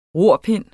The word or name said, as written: rorpind